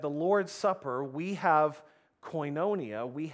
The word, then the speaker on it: koinonia: However it's translated, the Greek word is koinonia, or you've probably heard it pronounced before, koinonia.